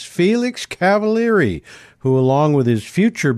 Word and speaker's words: Cavaliere: I found out that he pronounces the E at the end of his last name like an E, Cavaliere, just like I pronounce the E at the end of my last name as an E.